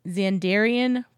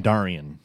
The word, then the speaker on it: Xandarian: And one of those first things is going to be the Xandarian Progress City. Did I say that right? – Xandarian. – Dang it.